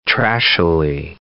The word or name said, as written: trashily